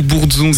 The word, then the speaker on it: Bourdzon: Ouais, donc on, va prononcer Bourdon presque.